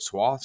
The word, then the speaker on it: swath: What worries me is that there's a large swath, how do you say that word? Swath? – Swath.